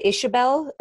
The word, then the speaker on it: Ixobel: Finca, it's called Finca Ixobel. Uh, it's spelled with an X, I X O B E L. Okay. In, in Guatemala, they pronounce the X like an S H and it's in Poptún Peten, Guatemala, and it's a beautiful place.